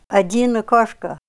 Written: ad'in akoshka

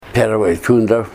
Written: Perway Tundra

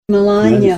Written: melanya

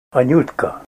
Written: anutka

